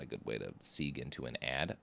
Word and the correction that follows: segue: Also, Sieg isn't the right way to say segue.